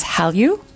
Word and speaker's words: Hallyu: So something called the Korean Wave, which is Hallyu. Am I pronouncing it right? – Yeah.